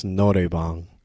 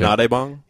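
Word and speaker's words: noraebang: Yeah, but in Korean, it's norebang. So that's how they say it. – I gotcha. – Norebang? – Yeah, norebang.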